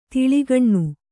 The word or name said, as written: ತೇಲುಗಣ್ಣು